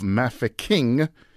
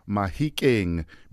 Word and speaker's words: Mahikeng: The handing over also affected the name change of this town from the British pronunciation of Mafeking to the Setswana name Mahikeng, meaning the place of stones, or boulders, if you will.